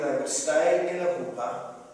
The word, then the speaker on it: chuppah: It looks like chupa, but it's pronounced chuppah.